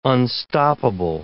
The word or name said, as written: unstoppable